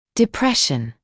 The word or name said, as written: depression